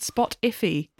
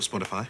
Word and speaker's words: Spotify: But the fact she just went Spot Iffy. – What is Spot Iffy? – We call it Spotify.